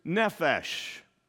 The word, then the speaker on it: nephesh: The Hebrew word is nephesh.